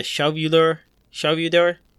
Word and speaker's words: Shelvador: He was actually, before we came on, like I was looking him up, he invented the Shelvador. – What? – Which was S-H-E-L-V-A-D-O-R. – Oh, so you were saying it right.